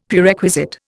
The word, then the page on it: Prerequisite: Indian